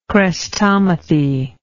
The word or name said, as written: chrestomathic